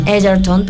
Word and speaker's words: Egerton: Y los 2 Globos de Oro fueron por Mejor Actor en Comedia o en Musical para Taron Egerton, se pronuncia. – Egerton, perfecto.